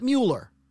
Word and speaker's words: Mueller: He spells his name M-U-E-L-L-E-R. Now, some people might pronounce that Mueller, but it's Mueller.